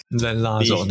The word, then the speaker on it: Lanzador: 所发表的最新的这个纯电二加二 crossover 啊，这一款车叫做 Lanzador，可以这样发音吧？看这看起来简单多了。 – Lanzador 对，Lanzador，OK，比之前的什么 Lebuye 好好发一点点。